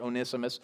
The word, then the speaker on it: Onesimus: you can pronounce it in many different ways. I pronounce it Onesimus.